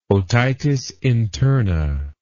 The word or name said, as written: otitis interna